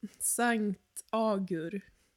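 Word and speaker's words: Saint Agur: Sankt Agur. – Som Ago. – Perfekta franska uttalet.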